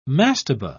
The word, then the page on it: mastabah: British English